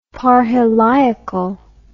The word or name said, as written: parheliacal